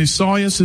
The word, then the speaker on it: Isaias: There is a hurricane that's going to be in the area, and obviously, I don't even know how to say it right, Isaias, Hurricane Isaias is how it says to pronounce it here.